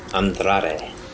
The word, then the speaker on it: Andrade: title against, how do you say it again? – Andrade. – That one. Oh, you say it with a little twang, too. Then you roll that R, and then you have R-Truth with Carmella, Rey Mysterio.